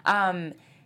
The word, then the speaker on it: Soderbergh: Am I saying it correctly? – Yeah, you're right. Soderbergh.